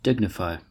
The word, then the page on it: dignify: American English